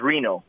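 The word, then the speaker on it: Rino: Well, my middle name is Frank, but my first name is Reno.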